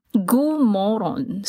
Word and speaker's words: god morgon: So you spell G-O-D, first word, morgon, M-O-R-G-O-N. It's not M-O-R-O-N. That is moron. You moron. So it's pronounced somewhat like moron, but it doesn't mean moron. So you say god morgon.